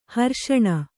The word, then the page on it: ಹರ್ಷಣ: harṣaṇa